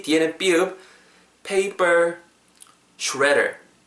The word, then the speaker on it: shredder: 제일 핵심적인 단어 S H R E D D E R shredder 가는 그 기계 파쇄기 shredder, shredder, shredder. 영국식은 뭐 shredder 정도로 되는데 오늘은 shredder, shredder 정도로 발음할게요. – redder 발음하실 때 R 사운드 입술 말고 하는 데만 좀 더 초점 맞추시면 좋을 것 같아요.